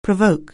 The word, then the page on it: provoke: anglais